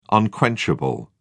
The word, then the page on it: unquenchable: British English